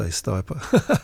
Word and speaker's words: Stipa: I think if you pronounce it, it's Latin. I think it's Stipa. If you speak to a lot of botanists, they say Stipa.